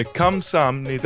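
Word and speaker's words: Kamsanida (감사합니다): I think apparently it's Kamsanida. No, Kamsanida. – Yeah, Kamsanida.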